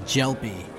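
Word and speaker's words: Gelpi: Myself, my entire family says Gelpy. – Jumpy. – Yeah, Gelpy. – Gelpy. – Gelpy, just like a gel pen and then P.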